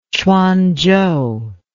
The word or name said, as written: quanzhou